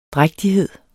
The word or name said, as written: drægtighed